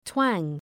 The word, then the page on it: twang: twæŋ